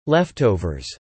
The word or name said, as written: leftovers